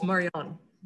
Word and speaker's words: Marjan: The J is pronounced like a Y, so it's Marjan.